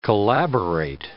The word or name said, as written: collaborate